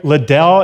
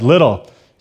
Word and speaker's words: Liddell: I said Eric Liddell in the first service, and someone said, no, it's not Liddell, it's Little.